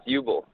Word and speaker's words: Ubl: Hey, yeah, you got it right the first time. It's Ubel.